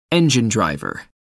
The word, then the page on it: engine driver: American English